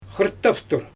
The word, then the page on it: xɯrttaftur: Xyzyl